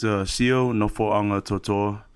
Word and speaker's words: Nofoagatoto'a: Yeah, it's Sio Nofoagatoto'a.